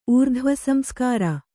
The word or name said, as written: ಊರ್ಧ್ವಸಂಸ್ಕಾರ